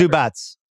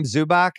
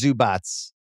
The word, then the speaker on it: Zubac: Zubats. I love calling him Zubac. As you know, I have pronunciation dyslexia, but it is Zubats.